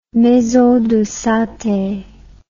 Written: maison de santé

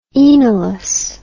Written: enalus

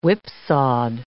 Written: whipsawed

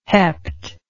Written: hepped

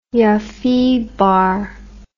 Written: ioffebar

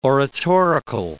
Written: oratorical

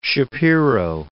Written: Shapiro

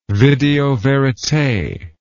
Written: video verite

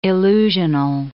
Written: illusional

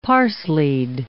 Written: parsleyed

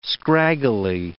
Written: scraggly

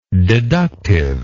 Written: deductively

